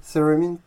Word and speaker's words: theremin: Like, it's not like a theremin. I don't know if I pronounce it well, theremin. – Yeah, that's right.